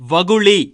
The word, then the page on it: வகுலி: Tamil